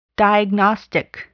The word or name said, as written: diagnostic